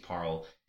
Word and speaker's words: Paarl: It's spelled P-A-A-R-L, Parl, but it's like that's just Paul.